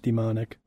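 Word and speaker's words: daemonic: Interesting, they spell it demonic. D-A-E-M-O-N. – I think that's still pronounced demonic.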